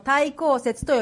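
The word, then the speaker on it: 待降節: 皆さん、世界中のクリスチャンがアドベント、対抗節というものをお祝いすることを知ってましたか？ – How many have heard the word Advent before? – まあ、日本語ではアドベントとも言いますし、対抗節というのは、待つという字に、降りるという字に、お祭りという漢字ではそうなんですけども、対抗節と読みます。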